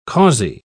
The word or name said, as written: causey